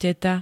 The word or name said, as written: teta